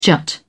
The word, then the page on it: jut: British English